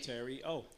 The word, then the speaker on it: Terio: T-E-R-I-O. How you say his name? – Terrio.